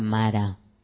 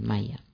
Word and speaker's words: Maya: Maya. O en algunas ocasiones se pronuncia Mara, pero es Maya.